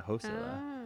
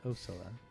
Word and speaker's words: Osilla: Yeah, Hosilla. – Oh. – Hosilla. – It's a silent H. It's Osilla.